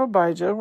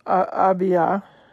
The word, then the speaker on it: Abijah: So, he was of the division of Abijah. We covered that. Or probably better pronounced, Abiah. Or Abiah.